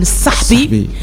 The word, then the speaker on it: الصحبي: أي طبعا ولكن النطق الشعبي يقول الصحبي